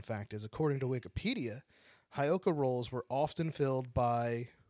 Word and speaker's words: Heyoka: I'm going to pronounce it like a white person. Heyoka. – Okay. – Because it's spelled H-E-Y-O-K-A. Heyoka.